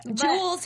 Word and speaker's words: Jewel: Why do you say it like that? – Jewel's her name. – Jewel. – Yeah, Jewel. – Jewel. – Oh my god! You said Joel.